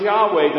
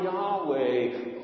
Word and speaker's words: Yahweh: Its pronunciation is an attempt to imitate the sound of inhalation and exhalation. So sometimes when I'm swimming, I'll simply breathe the sound Yahweh over and over.